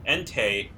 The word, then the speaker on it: Entei: So, just a clarification point, I listened to a clip from the anime, and the pronunciations are Raikou, Entei, and Suicune.